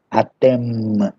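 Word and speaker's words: atem (אתם): Atem. Lembre-se que esse M no final é bem pronunciado, tá? Não pode assim falar atem, né? Atem, atem. Porque o feminino termina com N.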